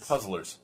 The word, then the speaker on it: Puzzler: Puzzlers. – Puzzlers. – Puzzlers. – Puzzlers. – Puzzlers. – League. – Fiddler. – Very good. – Puzzler. Fiddler. – Puzzler. – Puzzler. It's three syllables.